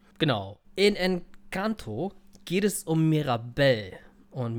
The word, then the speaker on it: Encanto: Und in diesem Fall, in diesem Jahr, ist das Ganze äh, äh, Spanisch. Encanto, Enchanto. Encanto. – Encanto. – Es, irgendwie Encanto. spricht man es aus.